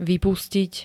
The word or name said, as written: vypustiť